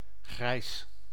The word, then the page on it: gray: /ɡɹeɪ/